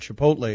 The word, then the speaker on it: Chipotle: Discover the new Subway Chipotle Chicken Melt with new guacamole. Subway, eat fresh. I get that right? – You did. Chipotle is yes. – how you pronounce it.